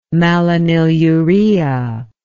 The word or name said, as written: malonylurea